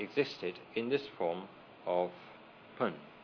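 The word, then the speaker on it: Bön: It's spelt usually, by the way, B-O-N, with two little dots side by side above the O. Sometimes it's spelled not with an initial B, but an initial P. But the actual sound is halfway between B and P. So it's pronounced in Tibetan Pun.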